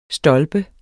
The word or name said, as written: stolpe